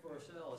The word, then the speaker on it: Versele: My name is Frank Forsell. It's spelled V-E-R-S-E-L-E.